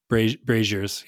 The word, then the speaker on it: brazier: How do you say, is it brazier? – Braziers, yep.